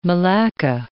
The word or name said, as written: malacca